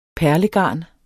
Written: perlegarn

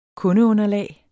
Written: kundeunderlag

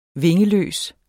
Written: vingeløs

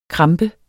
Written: krampe

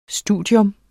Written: studium

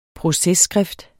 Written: processkrift

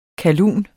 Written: KALLUNERNES

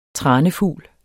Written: tranefugl

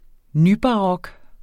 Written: nybarok

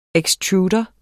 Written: ekstrudere

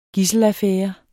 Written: gidselaffære

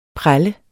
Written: prelle

